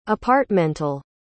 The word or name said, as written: apartmental